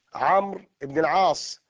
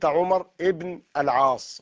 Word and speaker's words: عمرو: مثلا إذا قرأت عمر بن الخطاب عمرو بن العاص، فلو كتبت عمرو بدون واو لقلت عمر ابن العاص وهذا خطأ.